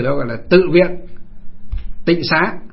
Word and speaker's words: tịnh xá: Tịnh xá là chỉ cho là nó gọi, bây giờ ngày xưa nguyên âm của nó gọi là tinh xá, nhưng bây giờ chúng ta đọc là tịnh xá.